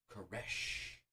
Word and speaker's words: Koresh: Uh, but they ask him his name, and he says David Koresh, and the guy uh, mispronounces it, and he's like, "No, Koresh, like a coffin nail." No, like a dying man's last breath. – Yeah. Oh yeah, like like a dying man's last breath. Koresh.